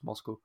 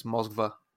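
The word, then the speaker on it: Moskva (Москва): Yeah, it's literally got a V in there that nobody ever pronounces because it's Moscow, but it's Moskva.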